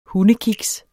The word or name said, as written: hundekiks